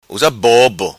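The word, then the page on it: Usa Bobo: Macanese Patuá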